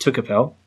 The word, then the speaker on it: Tucapel: And the rest of them were gathered a bit close to them, near places called Milaracupe and Tucapel. – Mm-hmm. – I think I'm doing okay so far. – Yeah, those pronunciations sound reasonable.